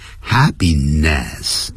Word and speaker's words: happiness: 这个 happiness 很多人在念的时候叫 happiness，不是老外呢，是这样念 happiness，它 N-E-S-S，它不会照着音标那个符号来念，它是念成 ness， happiness， happiness。